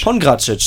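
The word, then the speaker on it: Pongračić: Pongracic, so wird er ausgesprochen. – Genau.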